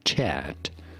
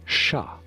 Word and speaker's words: chat: No, it is not chat. That's English, and that means something completely different. How do you go about saying this in France as chat? The T is silent.